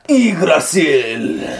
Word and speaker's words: Yggdrasil: Yggdrasil! – That was good. That was a nice pronunciation, man.